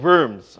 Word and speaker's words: Worms: And we stopped in Germany, of course, in the town or the city of Worms, or Worm, as we would say in English. And I hope that's the right way to even try to say it the way the Germans would. But it's spelled W-O-R-M-S.